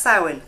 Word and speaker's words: Samhain: Marnie explains that it's Samhain, which they pronounce Samhain in the show, which annoyed me because they should have researched. They should have researched, shouldn't they? It's pronounced Samhain.